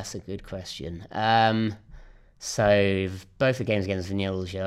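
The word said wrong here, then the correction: Vllaznia: They're actually pronounced Vlasnia. – Vlasnia, thank you.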